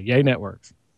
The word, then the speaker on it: Yay Networks: He's the VP of Operations and Engineering for Yay Networks. That sounds weird to say. Did I say it right? – No, you said it absolutely perfectly. Yay Networks.